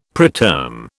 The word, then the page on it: preterm: British English